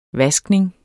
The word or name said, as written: vaskning